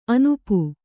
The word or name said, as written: ಆನೂಪ